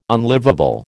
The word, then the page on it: unlivable: American English